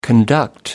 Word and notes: conduct: American English

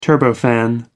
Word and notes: turbofan: American English

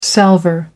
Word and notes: salver: American English

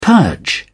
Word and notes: purge: British English

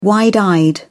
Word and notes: wide-eyed: British English

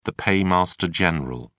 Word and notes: paymaster general: British English

